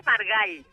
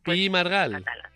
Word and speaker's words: Margall: Cuando pones la oreja, cuando se nombra este caballero, en el apellido generalmente se mete una A que no existe. – Bueno, ¿y cómo se, bueno, dice, ¿y cómo se dice bien? – Se dice Margall. – Margall. – Ahí pronunciando al final... porque él era catalán.